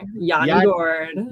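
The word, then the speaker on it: Yanni Gourde: I keep saying his name wrong. Sorry. – It's Yanni. Yanni Gord.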